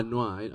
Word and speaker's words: noir: Is it noir? Yeah, noir. I always had problems pronouncing that word. – Noir.